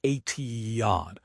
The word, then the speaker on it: Etihad: Gotham FC is moving to Etihad, pronounced Etihad Park in Queens on a 5-year lease.